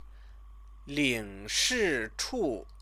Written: lingshichu

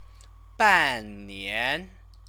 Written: bannian

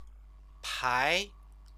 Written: pai

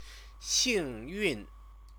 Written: xingyun

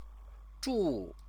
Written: zhu

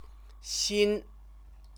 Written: xin

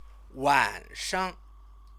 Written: wanshang

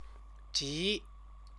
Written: ji

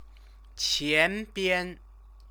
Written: qianbian

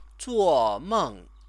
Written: zuomeng

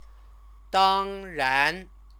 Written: dangran

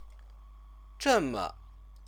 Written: zheme

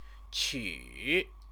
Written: qu

